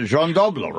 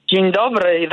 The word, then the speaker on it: Dzień dobry: I worked in a Polish neighborhood, and there was a Jean Dobra, right? Is that how you say it? – Dzień dobry, that's right.